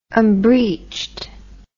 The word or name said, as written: unbreeched